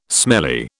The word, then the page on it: smelly: British English